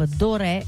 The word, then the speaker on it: Doré: Si chiama Storia della Santa Russia, Gustav Doré, con l'accento sulla E, scritto così come si pronuncia, è l'autore.